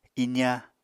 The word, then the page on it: iza: apatani